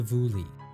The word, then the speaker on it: Boule: To start, we can note the existence of the Vouli, which is spelt B-O-U-L-E.